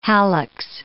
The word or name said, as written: hallux